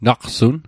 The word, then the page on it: naqsun: Mi'gmaq